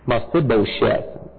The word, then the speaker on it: Mephibosheth: Mephibosheth would be the proper pronunciation.